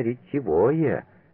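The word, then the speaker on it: ретивое: Обратите внимание, на какой слог падает ударение. Именно ретевое.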